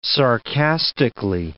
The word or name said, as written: sarcastically